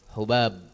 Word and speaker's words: Hubab: Qala haddathana Zaid ibnu Hubab, ya, bidhammil muhmala, dengan mendhamma al-muhmala, yaitu ha tanpa titik, ya, itu yang namanya ha, bidhammil muhmala wal muwahhadataini, dan dengan dua titik, yaitu ba setelahnya. Barakallahu fikum. Sehingga bacanya adalah Hubab, iya, tanpa tasydid.